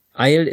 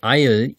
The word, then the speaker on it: aisle: Eil ist also schlicht und einfach ein Gang zwischen etwas hindurch. Das Wort ähnelt dem Wort Eil, I-S-L-E, was Insel bedeutet. Und es wird auch genauso ausgesprochen.